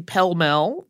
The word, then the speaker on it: Pell Mell: And two years later, Pepys interviewed the man who looked after what Pepys is by that time calling the Pell Mell. That's the yard used to play the game, and it's spelled the way it sounds P E L L M E L L.